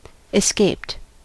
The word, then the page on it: escaped: American English